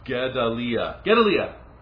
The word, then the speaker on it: Gedaliah: Gedaliah. I added a D in there for some reason.